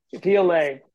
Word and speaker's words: Piolet: Did I say that right, Graham? – Piolet.